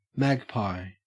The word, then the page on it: magpie: Australian English